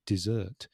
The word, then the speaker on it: dessert: The Sahara Desert and eating dessert. Mm. So just putting that stress in a different place, on a different syllable, you totally change the meaning.